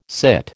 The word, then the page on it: sit: American English